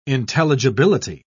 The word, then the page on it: intelligibility: British English